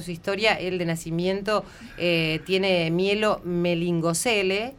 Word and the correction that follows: meningocele: lo pronuncié bien Matías. – Meningocele. – Meningocele, claro, porque tiene que ver con las meninges, ¿no? – Eh, eh.